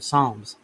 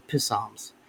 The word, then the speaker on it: Psalms: And Joe Biden doesn't even know how to say the word Psalms. He calls them pissalms, like Obama used to call the Marine Corps, Marine Corps.